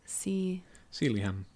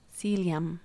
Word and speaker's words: Sealyham: How would you even pronounce that? – Sealyham. – Sealyham. – Like William. – William.